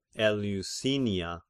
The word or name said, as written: Eleusinia